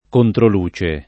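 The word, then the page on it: controluce: italiano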